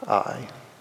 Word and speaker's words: Ai: Usually say this Ai. In the Hebrew, the A, the Aleph, is silent. So it's just Ai.